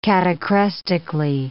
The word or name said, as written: catachrestically